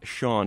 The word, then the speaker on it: Chan: Yes, it is pronounced Sean, even though it's spelled C-H-A-N.